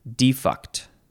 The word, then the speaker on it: defunct: Now, is it defunct or defunct? And it is spelled D-E-F-U-N-C-T. You have to be careful how you say this word, because it could come out like defunct. Now, this is defunct.